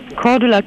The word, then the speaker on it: Cordula: Mein Name ist Cordula Kienes.